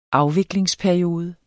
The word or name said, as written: afviklingsperiode